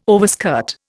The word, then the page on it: overskirt: Indian